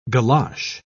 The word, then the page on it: golosh: British English